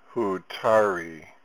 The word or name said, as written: Hutaree